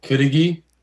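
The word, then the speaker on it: cudighi: It's called Kudiji. – Kudiji. – Or that's how they pronounce it in Michigan, at least.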